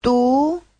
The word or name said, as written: du2